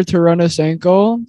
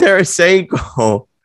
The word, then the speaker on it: Tarasenko: So I got Vladimir Tarasenko as my first honorable mention. – Butchered that. It's Tarasenko.